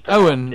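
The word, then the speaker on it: Eoin: On to the sixth book then, because this one's been written by, is it Ian Colfer? Is that pronounced? – Owen. Yeah. – Would you said that was Owen? – I wouldn't. No. – It's an Irish name. Owen.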